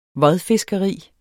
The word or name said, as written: vodfiskeri